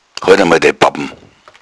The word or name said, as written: babbn